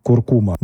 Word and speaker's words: куркума: В ней присутствуют гвоздика, кардамон и, конечно же, вездесущая индийская куркума. Да, я поставил ударение согласно словарной норме. Немножко уже устаревший, старомодный, но вот так было изначально.